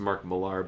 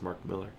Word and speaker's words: Mark Millar: Mark Miller, who people sometimes pronounce Mark Millar, but it is Mark Miller, he's got that deal with Netflix.